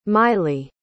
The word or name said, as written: maile